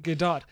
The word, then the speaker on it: Gadot: Are you sure you're saying that right? – Yes, I am. I googled it. Gadot. – I want to have it have the French spell, the French pronunciation, but I guess it doesn't.